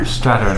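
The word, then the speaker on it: Strathairn: How do you pronounce his name? – I think it's Strattern. – Strattern, because I actually knew him from The River Wild.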